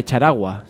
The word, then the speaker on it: Charagua: Y hoy nos vas a hablar, bueno, pues del proceso autonómico en la provincia de Charagua, si no lo he pronunciado mal. – No lo has pronunciado mal, solo que no es una provincia, es un municipio que está optando a cambiar el régimen de municipio a autonomía indígena.